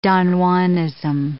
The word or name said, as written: Don Juanism